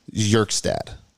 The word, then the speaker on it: Kjerstad: He's the one that started it, and he's the 69th overall prospect, Heston Kjerstad, which I think is pronounced Yerkstad.